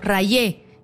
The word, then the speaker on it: Rayé: Ray, se escribe R-A-Y-E, como rayé.